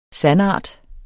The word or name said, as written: sandart